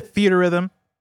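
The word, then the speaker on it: Theatrhythm: Theater, is it Theater Rhythm? – Yeah. – I'm mispronouncing it this entire time. I like Fiat Rhythm though. But yeah, Theater Rhythm.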